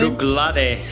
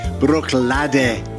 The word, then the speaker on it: Bruichladdich: Bruichladdich. – Bruichladdich.